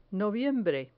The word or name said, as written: noviembre